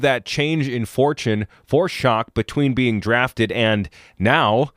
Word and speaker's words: Shough: Shock? I think it might be Shock, actually. S-H-O-U-G-H, but I remember seeing a pronunciation guide.